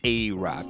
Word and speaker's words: AA Rock: And that's two A's. It's actually an acronym. DJ A-A-R-O-C-K. And it is pronounced A-Rock, not A-A-A-A-A-A. So, and all the social media, A-Rock, DJ A-Rock, and all that.